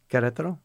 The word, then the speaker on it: Querétaro: Querétaro.